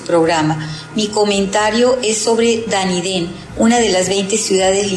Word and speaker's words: Dunedin: La ciudad de Dunedin se pronuncia Dunedin, significa castillo sobre la roca, que es el nombre galeico de Edimburgo.